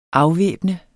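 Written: AFVÆBNEDE

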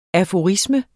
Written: aforisme

